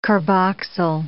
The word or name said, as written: carboxyl